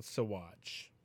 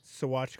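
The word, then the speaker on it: Saguache: Like, I love when people, like, we have a town in Colorado called Sawatch. And it's, you know, spelled like S-A-G-U-C-H-E. Like, Saguche. Sawatch, Colorado.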